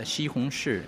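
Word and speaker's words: Xi Hong Shi (西红柿): Tomato in the last pronunciation is Xi Hong Shi. Shi means city.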